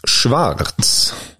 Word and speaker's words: Schwarz: Chippen och Schwarz. – Bra tyskt uttal där på Stefan Schwarz.